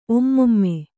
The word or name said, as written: ಒಮ್ಮೊಮ್ಮೆ